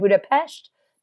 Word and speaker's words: Budapest: Oh, and I said, Budapest? That's how they say it here.